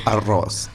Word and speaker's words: arroz: It's arroz. Yeah, you got to roll your R's.